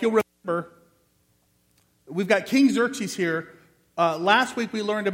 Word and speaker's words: Xerxes: But all those X's together, it's King Xerxes.